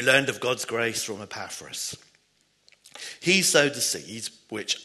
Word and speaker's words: Epaphras: So Paul, it was probably, as far as we know, during Paul's stay in Ephesus, that his co-worker, Epaphras, as you pronounce that absolutely right, Kathy, first brought the good news to Colossae and established a church there.